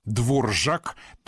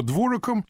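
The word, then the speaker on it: Dvořák: И все уже научились правильно произносить эту фамилию и не обращать внимания на странную птичку-галочку, которая стоит в ней над буквой «Р». Дворжак, так по-чешски пишется.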